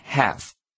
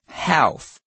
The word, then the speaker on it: half: 这边先注意到 half 念成 half 而不是 half, L 不发音, 请同学留意。再来, Remain 是保持什么什么状态的意思。Remain 是连缀动词，其后搭配名词或形容词。例如课文中 ,Monroe has remained one of the most respected short story writers.